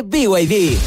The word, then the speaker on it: BYD: ¿No es BYD? – Nada que ver, se escribe BYD, pero se dice BYD, la marca número uno de electromovilidad en Chile y el mundo.